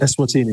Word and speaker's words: Eswatini: I wonder also, I mean, because I've been to South Africa, but I haven't been to what's now Eswatini. Is that how you pronounce it? – Eswatini.